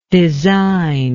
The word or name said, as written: designless